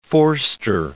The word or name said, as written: Vorster